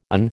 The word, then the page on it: an: American English